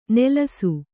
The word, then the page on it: ನಲಿಸು: Kannada